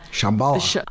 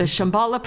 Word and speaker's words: Shambhala: Shambhala. – I know. I pronounced it wrong during the interview. I pronounced it wrong. Shambhala. The Shambhala Preserve, right? – Yes, yes.